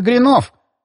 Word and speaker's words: гринов: «Один раз, правда, пришлось платить за ремонт гринов», — он сказал «гринов» с ударением на последнем слоге.